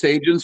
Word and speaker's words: Sajjan: And then even when Harjit Sajjan, and I've heard it pronounced Sajjan, so I'm going to go with that now.